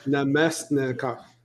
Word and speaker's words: Namestnikov: Namestnikov.